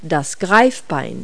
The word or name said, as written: cheliped